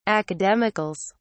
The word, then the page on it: academicals: British English